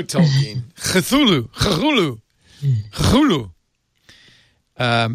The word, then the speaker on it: Cthulhu: Actually, I'm looking now, by the way, and apparently Lovecraft himself pronounced it Cthulhu. Cthulhu. Cthulhu.